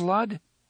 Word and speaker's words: Lud: "Have you heard of Lud?" Eddie asked. "Perhaps," the gunslinger said, "when I was very small." – "It rhymes with crud," Eddie said.